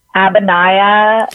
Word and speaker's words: Abhinaya: So you probably could go to Abhinaya, to their Instagram. – Yeah, how do we spell it? – A-B-H-I-N-A-Y-A.